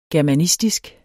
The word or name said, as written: germanistisk